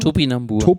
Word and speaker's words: Topinambur: Katharina hatte eine, ich kann das nicht aussprechen, Tombinambur, sagt man das so? – Topinambur.